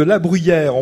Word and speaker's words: La Bruguière: Ça s'écrit La Bruguière, mais ici on dit plutôt La Bruyère.